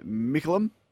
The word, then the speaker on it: Mickleham: And they are now selling in Mickleham. Did I say that right, Lordo? – That's right. Yeah. – Mickleham.